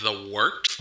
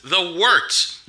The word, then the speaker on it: Wort: It is The Wort. Not Wort, not Fort, not Short. The Wort.